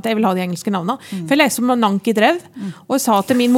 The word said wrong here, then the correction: Nancy Drew: Og så sa hun det uttales Nancy Drew, altså.